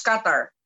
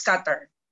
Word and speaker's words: Qatar: Is that how you pronounce it? – Yes, Qatar. It's Qatar.